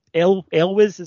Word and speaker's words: Elwes: It's got Elwes, is that how you pronounce it? Carrie. – Yeah.